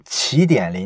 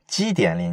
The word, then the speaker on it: 奇点: 这就是咱们今天要解读的这本书《起点临近》。这本书呢，我之前提过好几回，不过呢，我之前都是把这本书念成《基点临近》。